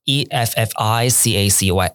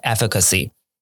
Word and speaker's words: efficacy: 啊，英文名词叫做 efficacy。好，你们可以学习一下，efficacy 是 e f f i c a c y。 e f f i c a c y efficacy，那这时候如果你把重音放错，你念成 efficacy，可能人家就会完全听不懂你在说什么。